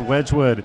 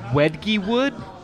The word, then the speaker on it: Wedgwood: It's not like the word wedge. It's not the thin end of the wedge. No. It's Wedgwood. – It's actually pronounced Wedgiewood.